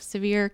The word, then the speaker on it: Sevier: She originally launched the organization in her home county of Sevier County, Tennessee. Am I pronouncing that right? – Yes.